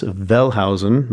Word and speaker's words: Wellhausen: His name is Julius Wellhausen. That's pronounced like a V because that's how Germans do it.